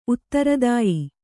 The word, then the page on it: ಉತ್ತರದಾಯಿ: uttaradāyi